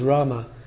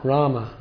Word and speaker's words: Rama: Srila Guru Maharaj, naturally they should chant Rama, not Ram, for that is the real pronunciation. If one pronounces it as Ram, that does not mean that the whole thing is spoiled, but Rama is the grammatically correct pronunciation.